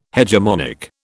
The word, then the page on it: hegemonic: British English